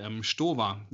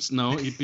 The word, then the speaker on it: Stowa: Not Stowa. – Because if you have an ST in German, it basically is pronounced as SHT, right?